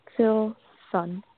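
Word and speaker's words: Filsan: Hi, my name is Filson. – Filson? – Yep, Filson. – Okay. – Susan, how do you spell that? – F as in Frank, I-L-S-A-N, like Nancy.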